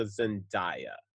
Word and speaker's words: Zendaya: I would redo this movie, but I would change out Jonathan Taylor Thomas for like a Zendaya.